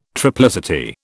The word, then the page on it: triplicity: British English